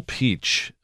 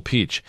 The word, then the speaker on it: Pietsch: This is a Bill Peach, Colonel Bill Peach. And that's spelled P-I-E-T-S-C-H. He led a commando team in World War II, died at 94 in September.